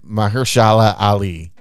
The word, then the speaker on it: Mahershala Ali: And this is starring Julia Roberts and Mahershala Ali.